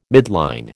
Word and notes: midline: American English

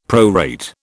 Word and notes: prorate: British English

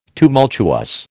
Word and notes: tumultuous: American English

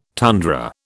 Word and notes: tundra: British English

